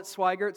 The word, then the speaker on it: Swigert: My last name is Swigert. And so Matt Swigert, and it's a name that sounds different than it's spelled, but that's all right. Generations have called it Swigert, so that's who I am.